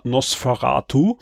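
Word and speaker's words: NOS4A2: Juni von man schreibt es NOS 4a2, ja, und äh, ausgesprochen wird es aber Nosferatu.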